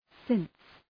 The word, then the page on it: since: sıns